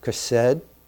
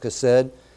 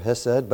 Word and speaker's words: chesed: Now that word, loving kindness, is a beautiful Hebrew word, chesed, pronounced, that's the way it's pronounced, chesed. It's written two different ways. It might look like chesed or hesed, but anyway, instead of the word kindness, some translations might say no love, no mercy, no loving kindness, depending on a translation.